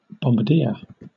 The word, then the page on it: bombardier: British English